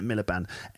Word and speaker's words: Miliband: No, Miller Band is spelled M-I-L. How do you spell it? Miliband. M-I-L-I-B-A-N-D.